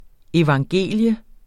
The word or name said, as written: evangelium